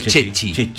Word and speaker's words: Ceci: Se llama Chechi porque se escribe Ceci en italiano. – Ah, claro. – Chechi.